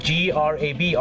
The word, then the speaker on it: grab: G R A B ออกเสียง grab คล้ายๆกับกินแกรบเนี่ยนะ